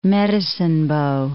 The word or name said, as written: Medicine Bow